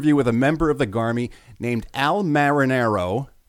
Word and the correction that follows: Manarino: No, I'm saying that wrong. This guy's name. Al. – Just call him Al. – I did during the whole interview. I never said his last name during the entire interview. Al Manarino.